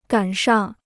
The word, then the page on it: 赶上: gǎn shàng